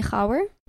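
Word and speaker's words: Khawar: Okay, that's the white people way to say it, though. Yeah, it is. – What's the right way to say it? – Okay, I don't want to, you know, get all weird, but it's usually Hawar.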